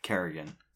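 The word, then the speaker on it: Kerrigan: Carrigan. It's Nancy Carrigan. We said that, right? – We said it right. – Did we say Carrington? – I think I said Currington because I was thinking Currig.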